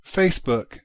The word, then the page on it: Facebook: prononco